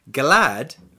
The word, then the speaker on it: glad: And in Svenska, glad rhymes with sad.